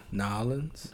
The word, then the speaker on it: New Orleans: How do you pronounce it? New Orleans? New Orleans? Nolans? – How I pronounce yeah. – it? Nolans. – Nolans. Yeah. I always say New Orleans. – I mean, you could say it anyway.